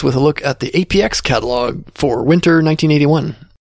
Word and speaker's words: APX: I've always pronounced it APX. That's what it says right on the cover. APX, but I have learned that the folks at Atari call it Apex.